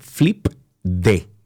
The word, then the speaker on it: FLIPD: Vamos a empezar con Flip, D. Se escribe F-L-I-P-D. FLIPD.